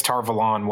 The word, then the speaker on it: Tar Valon: I suspect some of these are the way Jordan intended, because Tar Valon was apparently the way Jordan intended. He saw it was a take on Avalon.